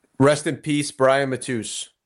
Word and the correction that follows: Mattis: Is that how you pronounce his name? – Mattis. – I thought it was Mattis. – Mattis? I always said Matus. Awkward. Rest in peace, Brian Mattis.